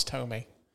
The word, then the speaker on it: Thome: She's like, I seen Jim Thome hit a home run. I was like, Mom, it's Thome. How do you get that out of how it's spelled?